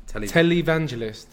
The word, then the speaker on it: televangelist: Televangelist.